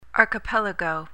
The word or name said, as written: archipelago